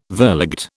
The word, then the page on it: verligte: British English